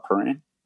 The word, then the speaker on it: Prahran: I'm saying that right, Prahran.